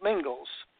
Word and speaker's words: Mingils: So for those people that want to date this and kind of get the accuracy here, my last name is spelt Mingils. M-I-N-G-I-L-S, how else would you spell it?